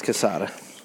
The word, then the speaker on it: Quesada: Yes, Quesada.